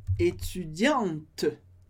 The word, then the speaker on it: étudiante: No caso do feminino estudante ou aluna, a gente tem a letra E, que não é pronunciada no final, porque a gente viu que o E no final a gente corta. Mas, com o E, eu pronuncio a letra T, que vem antes. Étudiante, étudiante. Então, masculino, étudiant, étudiant, e feminino, étudiante, com esse T no final.